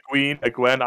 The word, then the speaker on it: Egwene: Engwen, is that how you say her name? – Egwene. I always say Egwene. – I say Egwene. But Egwene.